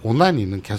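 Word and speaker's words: 오나니는 (オナニー): 꼭 그렇게 발음해야 됩니까? 뭐요? 마스터베이션은 음. 마스터베이션 이렇게 네. 발음했는데 네. 우리 죽돌군이 네. 오나니는 계속 아까부터 어나니 네. 그게 되게 거슬리는 게 예.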